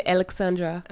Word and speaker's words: Ellxandra: C'est Ellxandra.